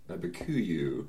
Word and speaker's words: Abiquiu: It's A-B-I-Q-U-I-U. – Abiquiu.